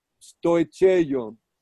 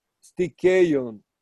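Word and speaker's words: stikeion (στοιχεῖον): La palabra rudimento es la palabra en el griego bíblico stoicheion, pero correctamente pronunciado en el griego moderna y posiblemente el griego como se hablaba en el tiempo bíblico hubiera sido stikeion.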